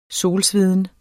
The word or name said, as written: solsveden